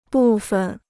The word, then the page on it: 部分: bù fen